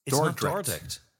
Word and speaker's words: Dordrecht: It's Dordrecht. – Dordrecht. There's an R in there. I didn't realise it until about 5 minutes ago.